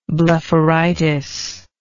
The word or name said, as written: blepharitis